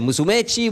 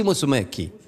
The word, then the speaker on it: Musumeci: Les voici donc, dirigés par, attention parce que alors je me trompe toujours sur la prononciation de son nom, Biagio Musumeci, come dice, Musumeci, Musumeci, Musumeci,